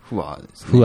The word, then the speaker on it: 不和: 父に勧められて人間病院を聞き始めたのですが、人間病院のおかげで、家庭不和って読むんですかね、これ。こう、不に。平和の和ですね。 – 不和ですね。 – 不和でいいんですね。